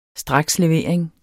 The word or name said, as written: strakslevering